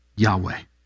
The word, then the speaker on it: Yahweh: Yahweh is how we usually pronounce it in English,